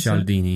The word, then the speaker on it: Cialdini: Is that how you pronounce Cialdini. – It? Cialdini. I still don't have the right accent.